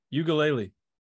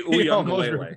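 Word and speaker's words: Uiagalelei: Ugalele. – That's not how you say it. – It's DJ Uyunglele. – Uyunglele.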